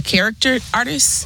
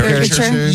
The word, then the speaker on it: caricature: I would like the I can't ever pronounce this word, the character artist. – Okay. Caricature.